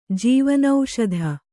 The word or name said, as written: ಜೀವನೌಷಧ